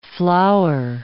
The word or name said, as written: flower